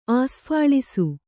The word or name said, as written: ಆಸ್ಫಾಳಿಸು